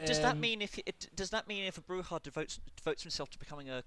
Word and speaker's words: Bruja: Okay, I'm 100% certain that's pronounced Bruja. Because it's the Spanish Bruja. – Word for witch.